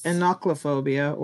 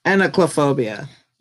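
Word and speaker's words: enochlophobia: And then the one that we were looking up before is Sawa's thing, which is anoclophobia, or wait, I think I pronounced that wrong as well. It is pronounced, hold on. I got the YouTube up. Hold up. – Anoclophobia.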